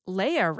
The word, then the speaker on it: Leia: Librarian Leia writes in, and she says, pronounced like the princess,